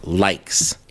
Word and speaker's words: Lyxe: Kicking off brand new music this week here on the show out of Lawrence, Kansas is a project called Likes. It is spelled creatively. It is spelled L-Y-X-E.